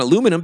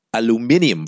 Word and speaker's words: aluminium: Then aluminum became cheaper than tin. That's why we have aluminum foil, or as the Brits say, aluminium foil.